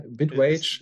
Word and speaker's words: Bitwage: Sie ging eine Partnerschaft mit Bitwag oder keine Ahnung, Bit wie der Swag, Bitwage.